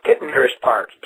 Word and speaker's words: Tittenhurst Park: It's Titanhurst Park. – Oh no! Yes, she's mentioning the song Tittenhurst Park.